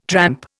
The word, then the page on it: tramp: Indian